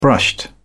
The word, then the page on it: brushed: British English